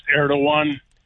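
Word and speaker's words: Erdoğan: His name is actually pronounced Erdogan. – Erdogan. – I know it's Erdogan. Completely different than the way it's spelled.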